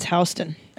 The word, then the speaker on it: Houston: If you're in New York City, it's Houston.